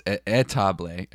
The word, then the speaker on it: étable: And now it's étable, I should say. – Like a table.